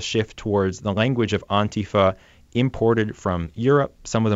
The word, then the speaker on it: Antifa: I've heard it so many ways, and you're saying Antifa. – Right. So like in Spanish, you'd say antifascismo, Antifa. So that's sort of a way that, that the Europeans say it, but I don't get in the habit of correcting pronunciation.